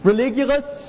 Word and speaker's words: Religulous: Yeah, his new movie, Religulous. It's supposed to rhyme with ridiculous.